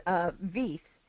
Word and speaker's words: Veith: But this guy, Walter, his last name is Veith. It's, you know, V-E-I-T-H, like Keith, K-E-I-T-H.